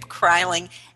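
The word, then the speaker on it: Kreiling: And we're here today with a true leader of transformation, Kip Kryling.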